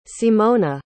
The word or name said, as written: simona